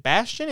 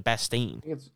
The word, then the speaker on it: Bastian: Nathan, how do you say Nathan Bastian? Is it Bastian? Is it Bastain? – I think it's Bastian.